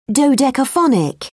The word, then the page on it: dodecaphonic: British English